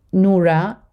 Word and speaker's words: Noura: My name is spelled Noura, N-O-U-R-A, Kevorkian, K-E-V as Victor, O-R-K-I-A-N.